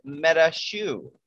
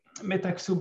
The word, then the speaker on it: MetaXu: And what is MetaShu? Am I saying that right? – It's an interesting one. I think there is different pronunciation. I would say MetaXu with MetaXu. a more accent on the X. MetaXu.